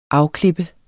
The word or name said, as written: afklippe